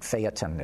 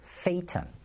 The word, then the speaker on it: Phaeton: I got to mention, Peter's rant this week on his website was all about the Volkswagen Phaeton, and now I'm being criticized for pronouncing it Phaeton. It's Phaeton.